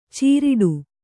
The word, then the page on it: ಚೀರಿಡು: cīriḍu